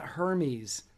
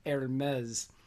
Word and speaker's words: Hermes: It's not Hermes like it's spelled, it's pronounced Hermes consign, uh, consign with them for a 30% fee, which is 20% less than most consignment stores.